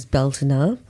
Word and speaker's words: Beltane: So I always learned it how I read it in books, because I didn't know any other witches or pagans, so it was always Beltane, which was the way that I could phonetically pronounce it. I know that it is Beltana. – In modern Irish, but whichever way we call it, like, what do you guys see Beltane or Beltana as?